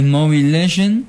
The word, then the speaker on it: Mobile Legend: El primero lo dijiste bien, es Mobile Legend.